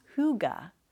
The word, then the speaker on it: hygge: Pronounced hygge, the word is said to have no direct translation to the English, though cozy comes close.